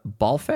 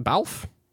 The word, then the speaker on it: Balfe: That is from the trailer of Black Widow, directed by Kate Shortland, written by Eric Pearson, the score by Lorna Balfe. Am I pronouncing that right? Balf. Yeah, definitely. It sounds like a Dutchman.